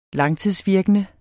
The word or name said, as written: langtidsvirkende